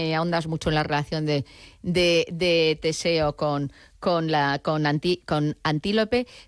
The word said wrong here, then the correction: Antíope: Pero lo he dicho mal el nombre, es Antíope. – Antíope, ¿verdad? – Antíope, Antíope, no, que la he llamado yo como un animal y no puede ser, Antíope.